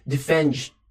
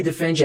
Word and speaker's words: defend: As pessoas têm uma tendência, tá? Brasileiros principalmente, a falar defend. We defend animals. Muito cuidado. É um padrão de pronúncia muito abrasileirado, tá? E que infelizmente não é muito bonito, tá? Ele soa estranho, ok? Então, we defend animals. Defend. Quase não sai som, tá? Então, we defend. We defend. É mudo quase, ok?